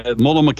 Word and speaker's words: Multnomah: Definitely heard, Multnomah County definitely got a lot of pushback, folks.